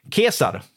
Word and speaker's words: Caesar: Det är något av det man lär sig när man läser latin, att eh, kejsare på latin uttalades förmodligen kesar, 'Kaiser'. Kesar.